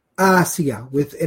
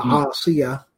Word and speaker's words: Asiya: Asiya. And even those who are particular about Arabic pronunciation, it's with an alif and not an ayn. The name with an ayn has not a good meaning. Asiya, basically.